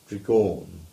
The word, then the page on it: Tregawne: English